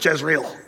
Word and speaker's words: Jezreel: It's because they don't pronounce it Jezreel. That's the way we do, J-E-Z-R-E-E-L, Jezreel. But if you go to Israel today, you'd have to say, where's the valley of Yisrael?